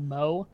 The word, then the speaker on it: Mo: Because that's my like childhood nickname, just M-O. Because, look, you need a one-syllable one when your name is Madrid, I guess. – But you made a good decision to write it phonetically. No one's getting it wrong.